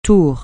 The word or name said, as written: tour